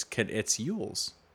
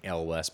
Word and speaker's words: Elwes: How to pronounce Carrie Yules. There's a lot of stuff online about it too. I've heard it before. It's Yules. Carrie. Yeah, this is saying L West, but that's not right.